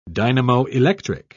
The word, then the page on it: dynamoelectric: British English